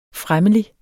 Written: fremmelig

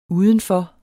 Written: udenfor

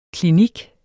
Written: klinik

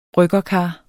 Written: bryggerkar

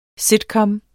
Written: sitcom

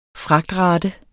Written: fragtrate

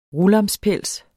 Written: RULAMSPELS

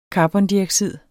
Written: CARBONDIOXID

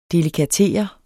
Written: delikatere

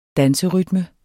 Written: danserytme

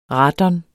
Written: radon

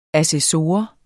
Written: ASSESSOREN

